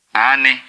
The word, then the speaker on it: ani: How do you say yes in Twi? – Ani.